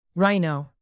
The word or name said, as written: rhino